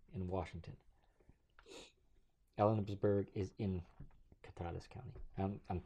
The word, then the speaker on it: Kittitas: K-I-T-T-I-T-A-S. On the continent of the Americas. – Sounds like Kittatas, right? – Kittatas, yeah. – Kittatas? – Kittatas.